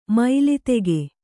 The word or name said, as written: ಮೈಲಿತೆಗೆ